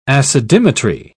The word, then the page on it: acidimetry: British English